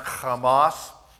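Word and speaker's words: Hamas (חמס): Now, this is the Hebrew word for violence. That's pronounced Hamas.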